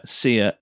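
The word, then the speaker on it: Ceer: I presume it's a soft C. – I would imagine it's a soft Sia. – Yeah, Sia.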